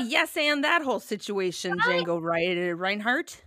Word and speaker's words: Django: I would like to remind our listeners that this dog is named Django after a musician named Django and not Django Unchained. – That's correct. I've never seen that movie. – You haven't? – No, the D is silent.